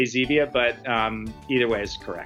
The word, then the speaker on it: Zevia: Once and for all, the pronunciation of your product is Zevia, correct? – Here's the funny thing. I say Zevia. Jim May, who actually brought the plant stevia to the United States, calls it stevia.